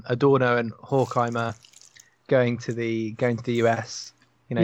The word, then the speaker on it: Horkheimer: Horkheimer. You need to put the emphasis on the first syllable.